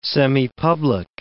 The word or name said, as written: semipublic